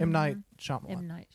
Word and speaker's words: Shyamalan: Imlan Shyamalan. I'm butchering that. Mm-hmm. M. Night Shyamalan.